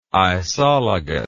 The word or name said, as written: isologous